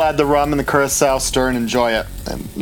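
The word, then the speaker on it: Curaçao: Curacao? How do you say that? I don't know how to say that. – Curacao. – There we go.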